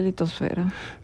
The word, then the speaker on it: litosfera: Según la RAE, soy horrible, litosfera. – La única que tiene la pronunciación que nosotros usamos como mexicanos es atmósfera. Lo demás debe ser hidrosfera, litosfera, biosfera.